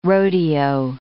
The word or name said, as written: rodeo